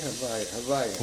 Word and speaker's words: Havaya: The Rebbe Shlita notes, this refers to the last three letters, Chai, Vav, and Chai, of this name, which form the words Haveh, Am I saying that right? Havaya. – Yeah, Yud, He, Vav, Ke.